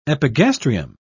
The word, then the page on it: epigastrium: British English